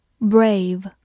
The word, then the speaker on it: brave: Brave. b r a v e，brave，字母 a 发它本身的音 a，v 发 v，末尾的 e 不发音。